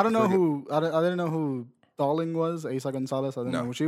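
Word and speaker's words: Eiza González: Aiza Gonzalez, yep.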